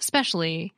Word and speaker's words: especially: A similar phenomenon happens in especially, pronounced specially. The first syllable is weak and is deleted.